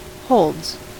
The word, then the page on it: holds: American English